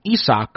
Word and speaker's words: Isak: And so people started calling him Isak because that's the German pronunciation.